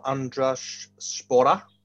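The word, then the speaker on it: Andraž Šporar: So I've gone for András Spora, which I'm told is the right pronunciation.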